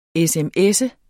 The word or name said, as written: sms